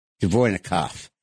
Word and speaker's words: Dvornikov: And they have now put Dvorniak am I pronouncing it right? We got to learn that name. Dvornikov. They have now put Dvornikov in charge of the Russian war effort.